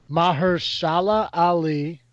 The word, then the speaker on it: Mahershala Ali: We got Mahershala Ali, which I think I said that right.